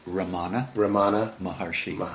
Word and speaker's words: Ramana Maharshi: Say his name again? Maharana Maharishi? – Ramana. Maharshi.